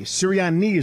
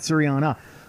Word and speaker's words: Sirianni: Sirianni is what we're looking for. I don't know how to say it, but it's not Sirianna.